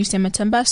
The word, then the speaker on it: Sematimba: Okay, so you can either type my name out, Sanyu Sematimba, so double S-A-N-Y-U, S-E-M-A-T-I-M-B-A.